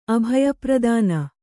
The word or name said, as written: ಅಭಯಪ್ರದಾನ